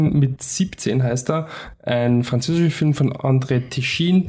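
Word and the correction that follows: Téchiné: der verfolgt uns schon Tichiné heißt das, gell? Oui.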